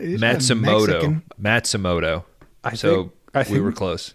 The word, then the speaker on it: Matsumoto: Matsumoto. M-A-T. S-U-M-O-T-O. Matsumoto.